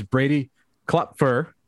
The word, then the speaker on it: Brady Klopfer: And like we said, here is Brady Klopfer.